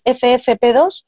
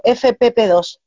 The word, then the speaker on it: FFP2: es FFP2 o FPP2? FFP2. Vale,